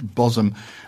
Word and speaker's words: Bosham: Last summer, my wife and Anne and I visited Bosham, which is spelt Bosham but pronounced Bosom, a small village on the outskirts of Chichester Harbour.